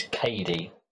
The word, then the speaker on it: Cady: Turns out, no, her name is Katie. Oh, it is Katie. C A D Y.